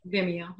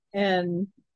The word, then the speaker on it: Vimeo: Vimeo. Vimeo.